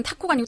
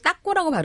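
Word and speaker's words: taco: 딱 정확하게는 타코가 아니고 따꼬라고 발음해야 된대요.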